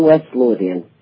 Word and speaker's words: West Lothian: Well, you are the owner of the Happy Valley Pub, and that's in West, now, I hope I'm pronouncing this correctly, West Lothian's Blackburn, Scotland, correct? – It's in Blackburn, West Lothian.